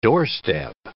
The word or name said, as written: doorstep